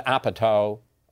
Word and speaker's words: Apatow: And they're almost like the Judd Apatow, oh, I said it right again, repertory company.